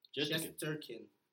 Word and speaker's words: Shesterkin: Shesterkin.